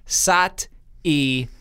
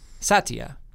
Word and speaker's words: Satya: I said, is your name two syllables? Satya. Sat-ya. Or three. Sat-i-ya. Satya. And she said it's a little bit in between. – It's two and a half syllables? – Well, no. She said it is two, but that E is still there.